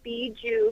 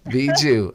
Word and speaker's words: Bijou: Just a quick note, it is Bijou Craft Kombucha, but we take anything at this point. – Bijou. Okay, I got it. And I'm going to make sure I say that correctly.